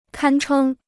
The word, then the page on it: 堪称: kān chēng